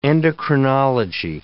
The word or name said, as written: endocrinology